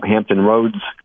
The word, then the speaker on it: Hampton Roads: We're looking at Virginia in the Hampton Roads area. Did I say that right? – Yep.